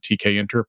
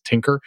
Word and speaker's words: Tkinter: I'm not sure whether this is a regional thing or where I just hang out, but I've often heard TK Inter pronounced as Tinker, which is like a purely dyslexic thing. So excuse me if I slip into that here. – Mine is TKinter.